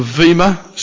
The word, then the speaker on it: bema: They set up a dais, you know, they set up a platform called the Vima seat, spelled B-E-M-A, pronounced Vima seat, and he sat on his Vima seat of judgment.